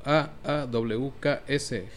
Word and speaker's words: AUX: Esta semana yo les voy a recomendar el nuevo disco de AUX, pronunciado como A-A-W-K-S, Heavy on the Cosmic.